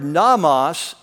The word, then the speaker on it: nomos: And the word namas is spelled N-O-M-O-S. Okay, so you have, and namas means law. Law. Yeah, L-E-W.